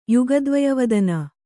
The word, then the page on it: ಯುಗದ್ವಯವದನ: kannada